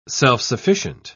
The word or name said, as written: self-sufficient